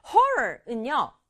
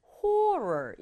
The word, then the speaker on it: horror: Horror은요. 끔찍함, 두려움, 공포 이런 뜻이에요. 그런데 이 단어를 어떤 사람은 horror 이렇게 O에 가깝게 발음하고 어떤 사람은 horror, I에 가깝게 발음해요.